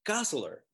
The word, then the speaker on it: Gosselaar: I have never been like quite sure how to pronounce his last name. – Gosselaar, right? I always start to question myself. – Gosselaar. Mark Paul Gosselaar.